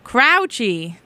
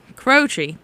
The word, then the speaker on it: Croce: Croce. Yeah, there's no other way to pronounce this pronunciation I've been sent. AJ Croce. Crouchy. No. Croce. Definitely Croce.